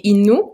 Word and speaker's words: Innu: Oui, en fait on dit Innu, le Innu, d'accord. – On prononce ou donc Innu de Pessamite.